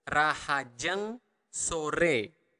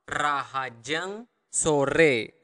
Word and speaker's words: rahajeng sore: So if you want to say good afternoon, is rahajeng sore. Again, you want to really pronounce clearly the R. Rahajeng sore. Rahajeng sore.